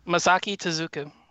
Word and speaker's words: Masaki Tezuka: Masaki Tezuka.